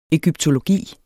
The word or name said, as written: egyptologi